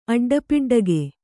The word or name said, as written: ಅಡ್ಡಪಿಡ್ಡಗೆ